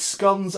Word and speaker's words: scones: Andy Walker said, it's scones as in rhymes with cones.